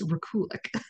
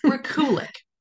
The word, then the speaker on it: Rekulak: We were mispronouncing his name, but yes, I looked it up and it is Rekulak. – Rekulak.